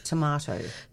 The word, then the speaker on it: tomato: Tomato. Put an H in there. M-A-H-T-O. – Tomato. – There you go. – Sauce. – You need to think of the H in there and then you'll say it right.